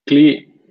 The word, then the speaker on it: Klee: Is it Klee or Clay? – Klee. – Klee. K-L-L or K-L-E-E-H, I think it is.